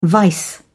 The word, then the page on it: vice: British English